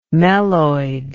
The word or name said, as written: meloid